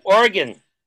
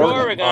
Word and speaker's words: Oregon: That means Oregon. That's how they say it. Oregon, not Oregon.